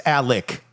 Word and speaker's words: Alex: But it's Alec, even though it's spelled Alex.